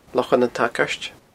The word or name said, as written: Lochan an t-Sagairt